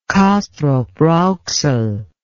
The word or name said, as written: kastrop rauxel